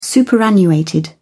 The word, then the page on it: superannuated: British English